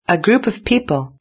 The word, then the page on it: a group of people: British English